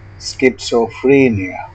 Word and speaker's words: schizophrenia: Schizophrenia. Say schizophrenia. With secondary stress on the first and primary stress on the third syllable. Use bit vowel, both vowel, beat vowel, and beer vowel. Stress pattern, dadi dadi.